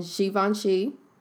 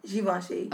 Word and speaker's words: Givenchy: Um, he's already shot for Marc Jacobs and Givenchy, and he said to I'm the, so glad you pronounced that correctly. What? Givenchy.